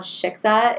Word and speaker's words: Xixa: One of my favorite restaurants is this place called Shiksa in Brooklyn. – Okay. – In Williamsburg. It's spelled XIXA and it's like the most amazing Mexican Asian fusion Ooh.